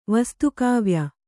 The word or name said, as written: ವಸ್ತುಕಾವ್ಯ